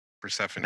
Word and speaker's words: Persephone: Persephone.